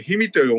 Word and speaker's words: 氷見: うん、 2 日目が白米の千枚田の棚田を見ていただいて、能登の長寿大仏から富山県の、富山県最大の漁港といったら氷見漁港、氷の見物の件、見ると書いて氷見と読むんですが、氷見の方で番屋街でお楽しみいただいて、富山の温泉に泊まっていただきます。